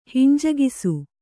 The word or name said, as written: ಹಿಂಜಗಿಸು